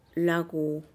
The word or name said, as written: laguu